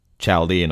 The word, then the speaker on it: Chaldean: surely this Chaldean should be pronounced Chaldean or Chaldean, as in the people of Chaldea rather than the fictional village in West Berkshire, which doesn't exist.